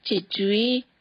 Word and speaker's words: 최초의: 이 회사 최초의 외국인이야. – 최초의 외국인이야. Like I said, usually pronounced 최초의, 최초의 외국인이야.